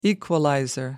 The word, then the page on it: equalizer: American English